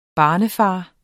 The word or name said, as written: barnefader